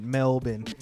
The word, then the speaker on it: Melbourne: That's how they pronounce it, Melbourne. – Melbourne. – Americans try to be like Melbourne.